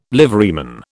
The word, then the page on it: liveryman: British English